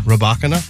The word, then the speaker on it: Rybakina: Coco lost to that Rybakina, I believe is how you say her name, correct? – Rybakina.